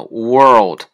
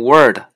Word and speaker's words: world: 所以是 which 类似的 when 啊，还有一个是我们中国人发音特别难的一个音，我我听到很多人发的都不是特别的标准，就是世界这个词单词啊 world，很多人发成 word 或者是 world，呃，这个都不对，它这个按照字母来发音，我们就知道，呃，第一个是。